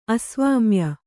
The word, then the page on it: ಅಸ್ವಾಮ್ಯ: kannada